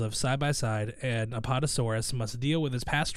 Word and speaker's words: Apatosaurus: A-P-A-T-O-S. – Apatosaurus.